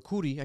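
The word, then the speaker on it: Coodie: So I think the Cody one, or the Cootie, I keep mispronouncing Cootie. Jesus Christ. – Like cooties. – My apologies. Cootie. Like cooties.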